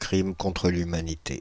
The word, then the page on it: crime contre l'humanité: France (Île-de-France); /kʁim kɔ̃n.tʁə l‿y.ma.ni.te/